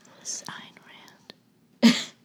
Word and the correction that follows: Ayn Rand: Ayn Rand. I'm pronouncing it right this time, I think.